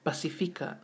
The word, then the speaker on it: Pacifica: So the P in Ngangana Samoa is almost pronounced like a B in English. – Mm-hmm. – So repeat after me. Pasi. – Pasi. – Fica. – Fica. – Pacifica. – Pacifica. – Pacifica. – Pacifica. – So we really emphasize the F-I in Pacifica. So that third syllable's really important to pronounce. So once again, Pasi.